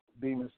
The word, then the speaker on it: Bemis: Am I saying that right, Bemis? – Correct, Bemis. Yeah, that's like I said, Bemis Boy Entertainment is the actual company, but it comes from where I'm from.